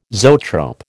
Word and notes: zoetrope: American English

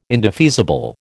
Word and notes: indefeasible: American English

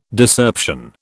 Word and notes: Discerption: British English

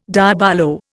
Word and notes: diabolo: Indian

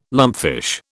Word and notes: lumpfish: British English

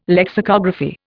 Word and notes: lexicography: Indian

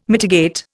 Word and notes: mitigate: Indian

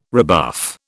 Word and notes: rebuff: British English

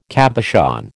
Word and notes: cabochon: American English

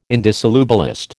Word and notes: indissolubilist: American English

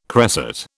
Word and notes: cresset: British English

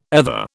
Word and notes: ether: British English

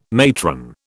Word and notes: matron: British English

